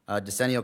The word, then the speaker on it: decennial: And I am going to turn it over to the township supervisor on the decennial, is that how you say it? – I looked it up and that is how you say it, yes.